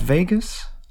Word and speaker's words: vagus: Is it pronounced Vegas? Vagus, yeah.